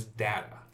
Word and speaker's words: data: Because the correct pronunciation is Data.